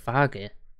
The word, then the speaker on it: Feige: I swear, it's F-E-I-G-E. – No, it's pronounced Fage. Everybody pronounces it Fage. – Fage. – Yeah. – It's F what? They're actually on pronunciation. F-Y, Fi, and then G. – Yeah, Fage.